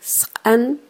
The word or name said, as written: sqan